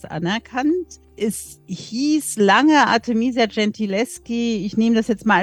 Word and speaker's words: Gentileschi: Bestes Beispiel, Sie haben vor kurzem auch erst ein Buch drüber geschrieben, die Artemisia Gentileschi. Ich hoffe, ich habe es richtig ausgesprochen. – Nicht ganz. Okay. Gentileschi heißt.